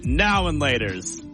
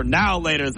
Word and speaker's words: Now and Laters: I say now and laters. You say now laters like it's all one word.